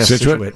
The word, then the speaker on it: Scituate: Yeah, Scituate.